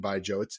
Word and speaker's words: baijiu: Long history and a really fascinating production process in China for making baijiu. It's spelled B-A-I-J-U, but pronounced baijiu.